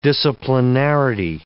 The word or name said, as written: disciplinarity